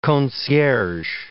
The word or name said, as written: concierge